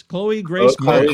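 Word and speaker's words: Chloë Grace Moretz: Chloe Grace Moritz.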